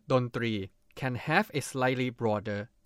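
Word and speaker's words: dondri (ดนตรี): Say it together with me, dondri. Dondri is a Thai noun, consists of two syllables. The syllable dri needs to be pronounced similar to the R sound in English.Another fun fact is that the word three actually means try or number three in Thai.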